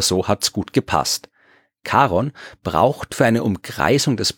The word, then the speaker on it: Charon: Christie, der wollte ihm den Namen Charon geben, vor allem, weil er damit seiner Frau Charlene eine Freude machen wollte, weil ihr Spitzname klingt ein bisschen so wie die amerikanische Aussprache von Charon.